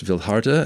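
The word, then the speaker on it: Vildhjarta: It's pronounced Vildharta, as I learned, not Vildjarta.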